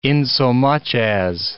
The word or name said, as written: insomuch as